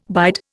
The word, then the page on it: bide: Indian